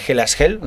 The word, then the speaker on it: Hellas Hell: es el de Hell as Hell, ¿lo he dicho bien? – Sí, muy bien.